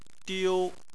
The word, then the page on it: diu: 1